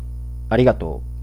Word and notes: merci: [mɛʁ.si]; Paris

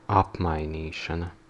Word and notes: exchange: /ɛks.tʃeɪndʒ/

